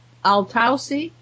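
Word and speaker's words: Altaussee: And, and then, of course, that the Ghent altarpiece was stored in the Altausi, is how you pronounce that. I don't know if you know. Salt mine in Austria. Altausi, salt mine. Were they? – Oh, yeah. Altausi.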